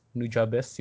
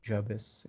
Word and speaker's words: Nujabes: Nujabes, you know Nujabes? No, how do you spell it? Nujabes. It's like this Japanese composer. Uh, Nujabes. N-U-J-A-B-E-S.